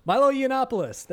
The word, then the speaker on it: Milo Yiannopoulos: Milo Yiannopoulos. – It's one of the hardest names to Milo Yiannopoulos pronounce. – Milo Yiannopoulos.